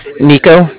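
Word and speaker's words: Niko: Nico, that's N-I-K-O. And I'm calling with the Bank of Cardiff. Cardiff is spelled C-A-R-D-I-F-F, as in Frank Frank.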